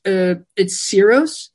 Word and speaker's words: Syros: So it's Syros. Syros, and the residency is called Syros Sound Meetings.